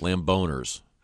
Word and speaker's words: Lamboners: Lambo Nurse. I don't get that. N-U-R-S? – Lamboners. – Oh, the Lamboners! – Lambo. Is it spelled like Lambeau Field, I presume? – Yeah.